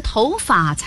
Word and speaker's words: 头发: 应该是头发才对。 – 所有在表示毛发的时候都读作发，比如长发、短发、剪发、染发、黑发、白发，唯独跟头搭在一起时读轻声，头发。